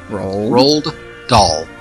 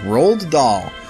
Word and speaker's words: Roald Dahl: Roll? Like rolled oats? – Roald Dahl. Okay, because it looks really weird when you see the spelling. Roald Dahl.